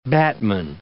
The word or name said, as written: batman